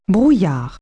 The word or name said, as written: fog